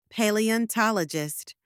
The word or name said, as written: paleontologist